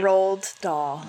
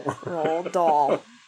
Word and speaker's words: Roald Dahl: Roald Dahl. Roald Dahl.